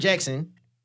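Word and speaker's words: Jaxn: Derrick Jackson, I guess that's how you say it.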